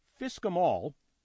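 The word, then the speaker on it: FSCK-em all: He hosts the podcast, which is pronounced Fisk 'em All. I have a link, okay? It's spelled F-S-C-K hyphen E-M space all.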